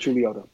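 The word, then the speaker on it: Chuluota: You're a seminary student in Chuliota. Am I pronouncing that properly? – That's correct, Chuliota.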